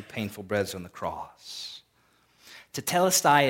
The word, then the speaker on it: tetelestai: It's pronounced tetelestai. If you want to write it down, you can kind of draw those Greek letters, or you can put T-E-T-E-L-E-S-T-A-I, tetelestai.